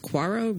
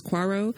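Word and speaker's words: Coiro: And another one, I believe his name is pronounced Quarrow, Reese Quarrow, who played Billy, the director.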